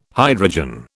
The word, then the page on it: hydrogen: British English